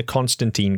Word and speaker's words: Constantine: For me, it's always been Constantine. That's how I've always read it. That's how it's been pronounced in popular media for many many years until Neil Gaiman has now decided that it's Constantine that rhymes with wine.